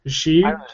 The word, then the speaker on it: Xi: How do you say that? Xi. Xi? Yeah.